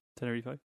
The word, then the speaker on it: Tenerife: Is that different to Tenerife? – Tenerife. Pretty sure that's how it's pronounced by locals.